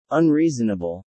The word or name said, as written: unreasonable